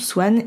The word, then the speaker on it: Xuan: Donc mon prénom s'écrit X-U-A-N mais se prononce Swan et ça signifie printemps en vietnamien, qui est donc euh, mon pays de naissance.